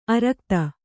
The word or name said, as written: ಆರಾಘಾತ